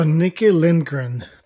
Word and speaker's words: Nikki Lindgren: So, Nikki Lindgren, did I pronounce that right? – You sure did.